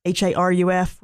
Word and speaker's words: Haruf: Kent Haruf, Haruf, rhymes with sheriff.